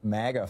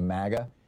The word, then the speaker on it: MAGA: By the way, have you noticed how Republicans are starting to change their pronunciation of MAGA? It sounds too close to maggot. So they're now starting to say MAGA, like, you know, hey, we're in Europe now.